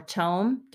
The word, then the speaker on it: Thom: It looks like Thome or Tome, depending on how you pronounce it. T-H-O-M. – Yeah. In the audiobook, they pronounce it Tom.